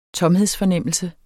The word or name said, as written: tomhedsfornemmelse